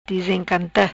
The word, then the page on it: Dizencanta: Patua